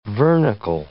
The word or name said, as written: vernicle